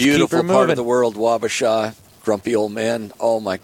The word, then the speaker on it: Wabasha: Wabasha.